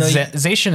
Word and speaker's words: Zacian: It's not Zacian. Well, how do you pronounce it? – Zacian is right.